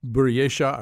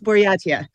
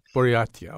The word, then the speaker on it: Buryatia: And no, unfortunately you didn't. It's Buryatia. – Buryatia, okay, got it.